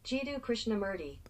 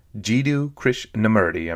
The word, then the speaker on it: Jiddu Krishnamurti: Jiddu Krishnamurti. – Jiddu Krishnamurti.